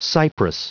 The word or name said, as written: cypress